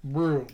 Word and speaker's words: Brugh: So, all right, that was Black Apple Brew, but it's spelled B-R-U-G-H.